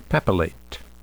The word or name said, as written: papillate